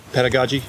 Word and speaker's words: pedagogy: Yeah, one of the things that a lot of us have appreciated about Greg is, you know, his approach to pedagogy. Am I pronouncing that word right? – Yeah. – Pedagogy? – Yes.